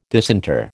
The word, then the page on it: disinter: American English